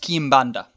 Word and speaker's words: Quimbanda: Yeah, I believe it's pronounced Kimbanda. – I don't know. How do you spell it? – Q-U-I-M. – Yeah. – B-A-N-D-A. – B-A-N-D-A. Kimbanda. I don't think I do.